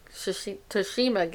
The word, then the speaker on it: Tsushima: I am over 80% trophies on my Tsushima game, though. – Ghost of Tsushima. – Tsushima, I can't say it right.